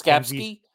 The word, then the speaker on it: Skapski: I mean, not everybody can pull a Mackenzie Skapski. Help me out with his last name. – Yeah, no, you said it right. – It was Skapski. – He had two Ks.